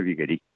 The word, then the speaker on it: Viguerie: Otro libro importantísimo eh, y además fácil, muy accesible, es Cristianismo y Revolución de Viguerí. Se escribe Viguerie, Juan de Viguerí.